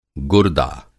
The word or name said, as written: गुर्दा